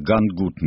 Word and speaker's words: Гангут: Суд над матросами линкора «Гангут». Меня учили, что так ударение.